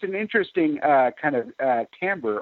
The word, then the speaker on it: timbre: By the way, that's the way that you pronounce that word. Some people, like on the talent shows, pronounce it as timbre, but it's actually timbre, and it means tone color.